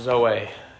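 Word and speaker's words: Zoe (ζωή): If you look at the Greek word for this word life, it's pronounced Zoe. It's, our spelling of it would be Z-O-E, but the meaning of this word that Jesus is using, it means that someone has the absolute fullness of life.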